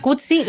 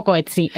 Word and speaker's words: Coetzee: Hablábamos antes de Coetzee, se pronuncia sí. Coetzee, lo conocéis como Coetzee, es el premio Nobel, ¿vale? Llevamos 1000 años pronunciándolo mal.